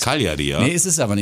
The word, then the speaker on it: Cagliari: Cagliari heißt's. Okay, ich hätte gesagt Cagliari, aber ich Nein, bin auch kein Stadionsprecher.